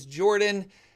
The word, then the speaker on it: Jorphdan: My name is Jordan, with a silent PH in the middle, and I am joined always by my wonderful co-host, Sir Lucian, over at Sir Lucian Gaming.